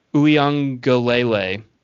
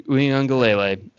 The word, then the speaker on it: Uiagalelei: DJ Uiagalelei, DJ Uiagalelei, the star freshman quarterback, getting like Russell Wilson comps, like a big Russell Wilson comp type of energy coming from him.